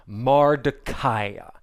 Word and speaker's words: Mordecai: Well, scholars believe that Mordecai's name is actually probably pronounced Mardukai, which is Babylonian, after the Babylonian um, god Marduk.